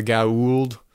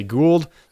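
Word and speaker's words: Goa'uld: Those are the main bad guys for most of the show, and everybody pronounces, well, there's a few different ways that they pronounce it. Sometimes they just say Gould. Oh. Sometimes they say Gauld. Gauld. And they can never establish a way to actually pronounce that the name of those guys, but which makes sense.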